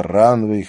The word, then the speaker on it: Ранвейг: Это Ранвей, хотя пишется Ранвейг.